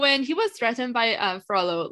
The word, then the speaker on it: Frollo: And when he was threatened by Froyo, I hope that's how you pronounce his name. – Frollo? – Frollo. I keep saying Froyo because I thought that it was like the Spanish way.